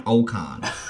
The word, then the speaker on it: Ocon: Okay, for the love of God, can you please pronounce his name correctly? Okay, it's Esteban Ocon.